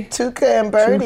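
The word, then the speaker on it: Tuca & Bertie: What's, how do you say this? Tuca and Birdie? – Tuca and Birdie.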